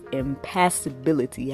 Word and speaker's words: impassibility: And in me asking that, I came across this whole idea of impassibility. Have anyone ever heard of that? It's called, it's spelled I-M-P-A-S-S-I-B-I-L-I-T-Y.